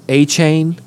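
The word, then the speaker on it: Achane: A-Chain? Is that how you say it? – Yeah.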